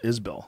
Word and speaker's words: Isbell: How do you say Isbell? – Isbell. Yeah.